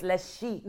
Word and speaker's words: Lezchic: If we're going to get, we got to pronounce it correctly. – Lezchic. – You ready? It's Lezchic.